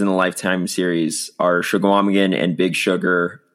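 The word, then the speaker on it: Chequamegon: You don't pronounce the G. It's just, it's like Schwamigan.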